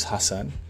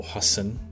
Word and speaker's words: Hassan: And yeah, they was basically, as you guys know, my name's Hassan, or Hassan, as my South Asian brothers out there would like to pronounce it.